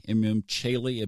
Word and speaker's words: Imum Coeli: And then the opposite of that is the IC, the Imum Coeli. You might hear it pronounced Chaeli, Imum Chaeli.